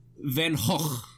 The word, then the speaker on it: Van Gogh: Pronounce it correct. – What? – Van Gogh.